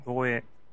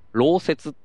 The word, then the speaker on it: 漏洩: 漏洩。 – 漏洩事故とかあるでしょ？ – 漏れるやつね。 – 漏れる、うん。漏洩って、もともと漏洩って読み方じゃなくて、漏雪っていう読み方らしいです。